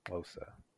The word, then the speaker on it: Xhosa: I want to learn like different words in the language, but it's pronounced Xhosa. Like you gotta Xhosa, like that.